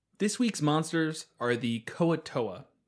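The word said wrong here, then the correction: Kuo-toa: Oh, I'm sorry, the Kuo Toa? Kevin, help me out here. – Yeah, Kuo Toa.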